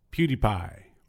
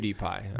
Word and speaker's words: PewDiePie: It's spelled P-E-W-D-I-E-P-I-E. PewDiePie. – Like CutiePie. – I think so. Or PewDiePie. – No, you don't say CutiePie.